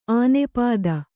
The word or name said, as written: ಅನಾಪ್ತ